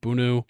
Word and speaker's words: Bounou: Yassine Bounou, I believe is how you pronounce it, Bounou, formerly of Sevilla, headed to Al-Hilal.